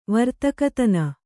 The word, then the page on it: ವರ್ತಕತನ: kannada